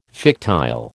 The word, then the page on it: fictile: American English